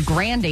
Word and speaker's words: Grande: So Grande is the correct pronunciation of that.